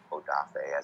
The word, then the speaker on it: Odafe: And then I'm not a big Jason Owe guy, or Odafe, as he wants to be pronounced now.